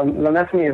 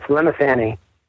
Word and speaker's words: Lemesany: So Lemesany is my real name. Jones is my... Oh, wow, now I'm even pronouncing it that way. It's Lemesany.